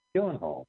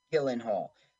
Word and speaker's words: Gyllenhaal: Gyllenhaal? What is it? – Jake, right? Or Maggie? – Yeah, what's the last name? – Gyllenhaal. – Gyllenhaal.